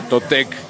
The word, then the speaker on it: Totec: Bueno, Totec, que está pronunciado en castellano, originalmente se pronuncia Totec, es en náhuatl, significa nuestro señor.